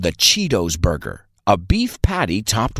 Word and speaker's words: Cheetos: Nosotros pronunciamos chetos con una I larga. Decimos Cheetos.